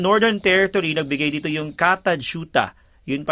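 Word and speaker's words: Kata Tjuta: Ito sa Northern Territory yun pala yung pronounce yung Katajuta.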